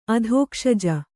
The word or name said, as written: ಅಧೋಕ್ಷಜ